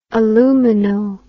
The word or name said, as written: alumino